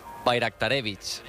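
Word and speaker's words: Bajraktarević: Bayraktarevich. – Bayraktarevich. O sea, la J la conviertes en una I, Bayraktarevich.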